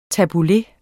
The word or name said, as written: tabbouleh